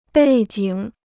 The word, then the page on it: 背景: bèi jǐng